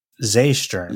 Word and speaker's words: Seestern: And it turns out that Seestern in German is the word for starfish. There you go. So we are pronouncing this thing wrong. Zeestern. Zeestern. – All right. – And it's it's uh it's a starfish.